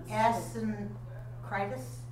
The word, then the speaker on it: Asyncritus: Can I pronounce that? – Asyncritus? – Yeah.